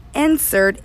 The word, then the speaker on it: NCERT: So again, NCERT, N-C-E-R-T, look into that, people, because it's an exclusive for superintendents.